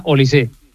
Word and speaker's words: Olise: No, es francés, es no lleva acento, pero se pronuncia Olise.